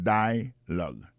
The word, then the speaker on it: dialogue: Alors, mot dialogue, ça c'est dialogue. Ça nous a remarqué là, nous a remarqué que l'accent tonique là tombe sous premier syllabe là.